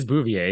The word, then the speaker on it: Bouvier: You got the name right. It is Bouvier.